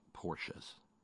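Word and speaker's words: Porsche: And yes, I like Porsches. And yes, it's a two-syllable word.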